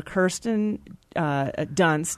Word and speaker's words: Kirsten Dunst: And it's its 20th anniversary this year, with Winona Ryder, Kirsten Dunst did I pronounce that right? – That's right.